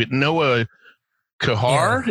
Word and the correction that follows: Kahar: Yeah. – Is Care. – Care? Is that how they pronounce it? – Yeah, it's Care.